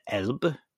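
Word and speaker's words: Alb: And they live in a really, really beautiful part of Germany in the Swabian, not Alps, but the Elbe, which is the same word as Alps, but they pronounce it with a B for whatever reason.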